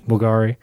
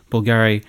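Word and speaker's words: Bvlgari: Bulgari. – You don't pronounce the V, it's a U.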